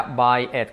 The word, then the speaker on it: buy it: Fijaros, curioso cómo se pronuncia cuando se pone con it, que todos los verbos van así, cómpralo. Cómpralo en presente sería buy it, como valleta.